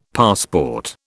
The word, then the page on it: passport: British English